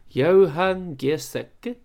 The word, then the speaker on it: Giesecke: I forgot how to pronounce his name. It's Johan Giesecke. – Giesecke. – Giesecke. – That's a bit more Spanish, isn't it? – Giesecke sounds like an Italian liqueur.